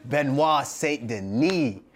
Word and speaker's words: Benoit Saint Denis: Benoit Saint Denis, like Ben Denis.